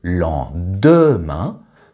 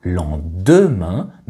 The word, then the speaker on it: lendemain: Par ailleurs, les deux lettres DE de lendemain sont aussi mangées et remplacées par un N. Ainsi, Marie-Christine ne prononce pas lendemain, mais lendemain. Lendemain, avec la lettre N en plein milieu.